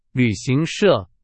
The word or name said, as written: 旅行社